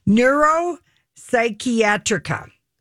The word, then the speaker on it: Neuropsychiatrica: Oh, not that one. – I don't know how to say this word. Let me try it again. – That's your Danish. – Neuropsychiatrica. – Oh. – ACTA Neuropsychiatrica. Wow, that's a mouthful.